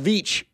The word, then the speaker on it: Veach: It's pronounced Veach, by the way.